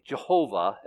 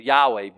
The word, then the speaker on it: Yahweh: His name means Yod-Heh-Vav-Heh, Jehovah, as we would say, or Yahweh, better pronounced, right?